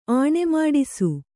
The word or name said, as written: ಆಣೆಮಾಡಿಸು